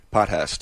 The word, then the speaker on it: Potthast: Potthast.